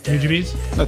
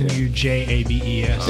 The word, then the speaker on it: Nujabes: I have some real like high school memories of lo-fi hip-hop, listening to like Nujabes. I'm probably pronouncing that wrong, but I think that's right. – Nujabes? – I think that's right. – Nujabes.